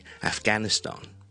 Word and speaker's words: Afghanistan: 我们先讲英式英文， BBC 通常呢个发音呢是会这样读的，大家听我读一次， Afghanistan, Afghanistan, Afghanistan。串法当然是 A-F-G-H-A-N-I-S-T-A-N, Afghanistan。